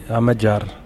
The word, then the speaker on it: Amadjar: Et ce disque donc qui sort, qui s'appelle Amadjar, comment on en prononce ? – Oui, Amadjar.